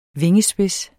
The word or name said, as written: vingespids